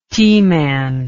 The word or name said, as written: T-man